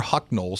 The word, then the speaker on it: Hucknall: Michael Hucknell is, or Hucknall, some people pronounce it both ways, okay, is the lead singer of Simply Red, and he really is the band.